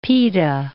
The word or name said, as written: pita